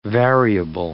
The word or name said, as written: variable